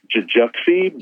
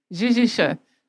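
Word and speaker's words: Jijuxie: And it's it looks like, well, it's spelled, I guess, in the romanticized version, or romanized version, it's J-I-J-U-X-I-E. So it looks like, I guess, Jujuxi, but it's pronounced Jijisha.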